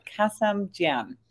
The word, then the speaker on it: Kassemjian: Kassemjian.